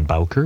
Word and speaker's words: Bowker: My name is John Bowker, B-O-W-K-E-R. My mailing address is 14 Canoe Brook Drive, and that's spelled C-A-N-O-E, Brook Drive.